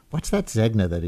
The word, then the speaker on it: Zegna: I was reading it phonetically as Zegna, I'm told, by people much more familiar with runways, I guess, than I am, that it's Zegna.